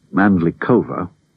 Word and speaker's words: Mandlikova: And when somebody jokingly reminded Mandlikova, for purists, by the way, that's how she pronounces it, when somebody reminded her that she was the oldest of this quartet, she sighed with no apparent irony.